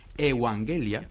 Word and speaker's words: evangelia: dice evangelia, que se dice así en griego.